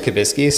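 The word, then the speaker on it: Kubiski: My last name is Kibiski, so okay.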